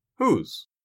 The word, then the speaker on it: who's: In natural English, who is sounds like whose.